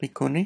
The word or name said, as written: pikuni